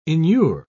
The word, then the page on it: inure: British English